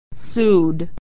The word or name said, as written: sued